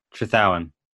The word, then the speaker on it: Trethowan: So, yeah, so it's Trethowan. – Trethowan. – Yes. – I probably would have gone with that, actually. I probably would have put a bit more emphasis on the H, but...